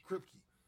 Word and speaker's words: Kripke: So the article reads, Supernatural star Jensen Ackles is jumping from one Eric Kripke, right? That's how you pronounce Kripke. – It. Kripke.